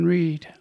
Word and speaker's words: Reid: And you can also follow me on Twitter at Father John Reed. R-E-I-D is the proper spelling of the last name, and Father is spelled out.